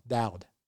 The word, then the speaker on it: Dowd: It's D-O-W-D. How do you say it? Dowd? Dowd. Dowd. There you go.